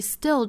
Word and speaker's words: still: 好，我们再把这句话里面的一些舌侧音再发一下 million million，美音呢是 million million， people people 跟后面 I 连读就是 people lie people lie，后面的 still still 啊，注意 still 这个词还要注意，就是中间的这个字母 t 不要发成 t，应该是类似于一个 d 的发音，所以是 still still still， s 后面的清辅音是不送气的，不要发成 still，应该是 still。